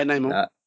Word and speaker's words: Namor: Namor? – Yeah, Namor.